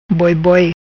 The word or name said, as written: Boiboi